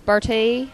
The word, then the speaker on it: Bertie: Bertie, Rowan.